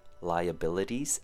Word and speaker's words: liabilities: And our last one for pronunciation would be liabilities, five syllables. Lie, as in you're lying. Lie, short U sound. B, short I sound. L, kind of sounds like a short U sound. And T's. Try saying the word D's, spelt D-E-E-Z.